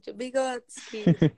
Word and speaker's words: Vygotsky: Vygotsky.